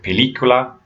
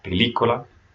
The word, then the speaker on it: película: So when you see those, or like a word like película for movie, there's an accent on the I, película, make sure you stress that sound.